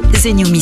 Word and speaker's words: TheNewMe: Zenyumi s'écrit THENEWME.